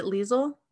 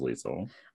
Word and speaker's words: Lisle: Is it Liesel? – Yeah, I thought it was Liesel. – Okay, because the way they spell it doesn't look like Liesel.